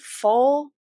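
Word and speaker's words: Pohl: Lucy, I believe her last name's pronounced Full. She's a Overwatch Mercy voice actress.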